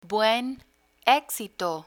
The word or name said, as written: buen exito